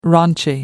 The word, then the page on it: raunchy: American English